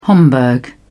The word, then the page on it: homburg: British English